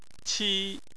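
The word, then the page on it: qi: tone 1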